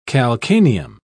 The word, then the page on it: calcaneum: British English